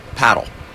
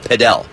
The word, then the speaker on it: padel: Around the world, it's pronounced paddle. But here in the United States, we have other paddle sports, and it's very confusing. So we decided to make it a little fancy and pronounce it padel. We invented that.